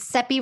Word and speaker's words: Seppi: My name is Seppi, as Valentina said, and as I like to tell people, it's Seppi rhymes with Peppy, just so you know how to say it if you ever see me.